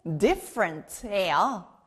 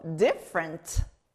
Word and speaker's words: different: F 소리 나는 다음 단어 배워볼게요. 바로 다른, different예요. 여기 F 소리 들리나요? 우리는 모두 다른 성격을 가지고 있고요. 생긴 것도 달라요. 다른, 영어로 different.